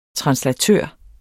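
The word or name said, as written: translatør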